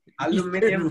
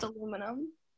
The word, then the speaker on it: aluminium: Aluminium is the right way to say aluminium because it is aluminium. – Now it's aluminum. – It's not aluminum.